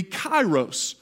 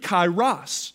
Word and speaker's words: kairos: The more appropriate Greek word would be kairos, or depending upon your pronunciation of the Greek, kairos. Kairos or kairos, which means a unique moment in time.